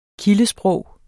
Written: kildesprog

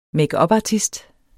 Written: makeupartist

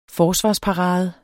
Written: forsvarsparade

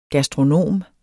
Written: gastronom

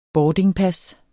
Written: boardingpas

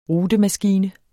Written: rutemaskine

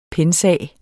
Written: pensum

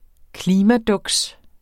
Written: klimaduks

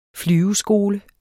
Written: flyveskole

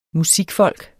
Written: musikfolk

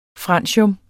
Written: francium

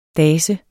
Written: dase

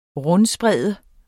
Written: rundsprede

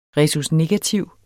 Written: rhesusnegativ

